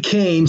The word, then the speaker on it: Koehn: And for folks who are listening, and as I often say about Warwick, since he has a silent W in the middle of his name, Nancy Kane, so you know, is spelled, listener, K-O-E-H-N.